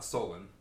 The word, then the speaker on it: Solon: there's another one in Salon. Am I pronouncing that right? Mm. Uh, Solon.